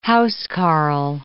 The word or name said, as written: housecarl